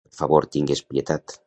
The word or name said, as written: tingues